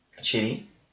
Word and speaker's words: Chidi: Chidi.